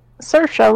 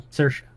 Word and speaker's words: Saoirse: Is Saoirse, like inertia. Yeah. – Saoirse.